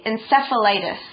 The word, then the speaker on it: encephalitis: Today's article starts out by saying Susanna's disease is called anti-NMDA encephalitis. – Wow, this is really hard to pronounce. – Mm-hmm. – Okay, 好，我们终于要来认识一下这个 Susanna 的病症呢，到底是什么？它被称作什么呢？抗 NMDA 受体自体免疫脑炎，然后英文我觉得蛮难，就是 anti-NMDA encephalitis. Can you say it again for me? – Encephalitis.